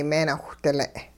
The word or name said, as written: meenawhdile'